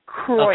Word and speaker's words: Kreutz: Did I say it right? Krutz? – No, that's okay. Kreutz. – Okay. Kreutz. Kreutz. Kreutz. That's kind of like French. Kreuz. Okay. – It's actually German for cross.